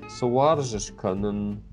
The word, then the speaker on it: Суаржишканен: но ударение, тем не менее, будет на «э». И у нас получится так. Суаржишканен.